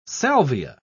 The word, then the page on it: salvia: British English